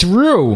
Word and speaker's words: Ryu: I'm gonna flat out ask, who calls the Street Fighter character Ryu? – I do. – Ryu. What? – Ryu. – It's Ryu.